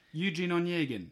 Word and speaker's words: Eugene Onegin: Tchaikovsky's, how do you say this? Eugene Onegin. That's it.